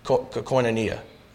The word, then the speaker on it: Koinonia: Koinonia. That's the American way of pronouncing it.